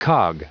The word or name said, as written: cog